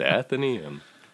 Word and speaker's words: Athenaeum: That's right, Athenaeum. It's pronounced Athenaeum.